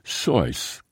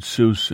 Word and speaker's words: Seuss: He used his mother's maiden name, Seuss, which was pronounced Seuss, the way it would be said in Germany. But people kept mispronouncing it Seuss, and eventually Dr. Seuss embraced the mispronunciation.